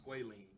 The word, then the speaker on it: squalene: Real quick, breaking news: it's pronounced squalene.